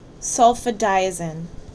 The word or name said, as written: SulfaDIAZINE